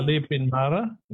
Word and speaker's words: Le Pin Mara: Did I say that correctly? – Yeah, Le Pin Mara.